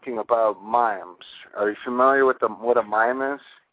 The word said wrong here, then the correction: meme: No, M-E-M-E. Maybe I'm pronouncing it wrong. – A meme? I think it's pronounced meme.